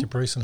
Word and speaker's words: Debrecen: So the other round, second round, was in – I believe it's pronounced Debrecen. – Debrecen, Hungary, yeah.